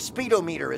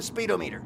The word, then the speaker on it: speedometer: But there's only one D. You're saying speedometer. – I'm saying speedometer. – Nope, it's pronounced like thermometer or van meter.